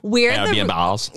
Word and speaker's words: Airbnbs: We own it, but we're filling it with VRBOs and Airbnbs. – Mm-hmm. – Is that how you say it? – Airbnbs.